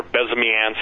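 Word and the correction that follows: Bezmenov: Bezmianov, yeah.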